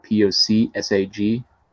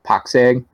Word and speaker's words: POCSAG: So the actual official name for when we're talking about like pagers and stuff like that uses a protocol called POCSAG. Poxeg is usually how I'm saying it, probably saying it wrong.